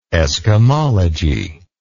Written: eskimologist

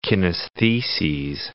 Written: kinestheses

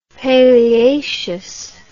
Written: paleaceous

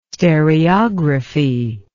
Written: stereography

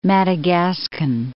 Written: Madagascan

